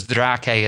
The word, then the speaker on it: Draque: And it's a little bit of play on words because Draque is sort of spelt Drake. If you pronounce it in English with that spelling, it looks like Drake.